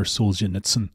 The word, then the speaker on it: Solzhenitsyn: Okay, first few times I said that second name, I don't think I got it right. So Alexander Solzhenitsyn, I hope you appreciate that. That's a well-practiced pronunciation on my part.